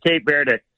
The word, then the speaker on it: Cape Verde: They in Spanish, it's Cabo Verde. – Cabo Verde. – Cabo San Lucas or whatever in Mexico. Cabo Verde. But okay. The English pronunciation is Cape Verde.